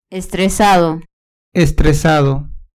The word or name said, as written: estresado